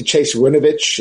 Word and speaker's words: Winovich: He's the Chase Winovich. Is that how you pronounce his name? – It is.